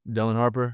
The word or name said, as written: Dylan Harper